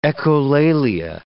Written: echolalia

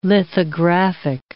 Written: lithographic

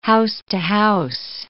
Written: house-to-house

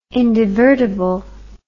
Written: indivertible

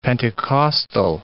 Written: Pentecostal

